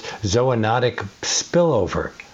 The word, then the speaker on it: zoonotic spillover: And the prevailing theory among scientists is, now I'm probably going to mispronounce this, zoonotic spillover. – Very good. You did it very well.